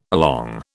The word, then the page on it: along: British English